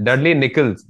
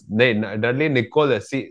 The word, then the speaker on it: Dudley Nichols: Are you sure he's called that? Because the way you pronounced it, I think his name is something else. – Dudley Nichols. Dudley Nichols. N I C H O L.